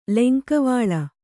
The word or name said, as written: ಲೆಂಕವಳಿ